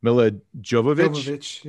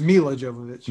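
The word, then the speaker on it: Milla Jovovich: Anderson's Monster Hunter with Tony Jaa, Ron Perlman, and I always get her name wrong, Mila Jovovich. – Yeah. – So it's Mila Jovovich.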